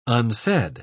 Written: unsaid

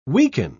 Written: weaken

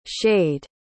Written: schade

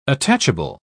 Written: attachable

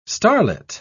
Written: starlit

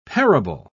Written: parable

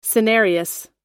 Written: senarius

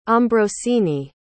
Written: ambrosine

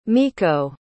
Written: mikko

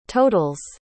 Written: total's

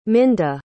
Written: minda

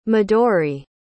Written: midori

